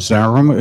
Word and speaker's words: Zarum: For the record, my name is Michael, M-I-C-H-A-E-L. Last name is Zarum, it's spelled Z with a Z, like a zebra, Z-A-R-U-M.